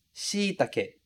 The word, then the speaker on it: shiitake: Veja que a ênfase está lá no shi. Shiitake. Shiitake. Outro cuidado muito grande que você tem que ter, que é extremamente importante, é esse e do final ser pronunciado realmente como e. E a gente não transformar em i, como algumas vezes a gente acaba fazendo. Então, shiitake, com e no final, sem transformar em i. A palavra em português acaba virando shiitake, shiitake, com i. E o ideal é a gente pronunciar shiitake, com e no final mesmo, muito bem pronunciado.